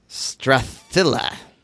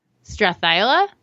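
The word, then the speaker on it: Strathisla: I'm going to give this a try. All right. Strathila. – Strathila? – Yeah, that's it. You got it.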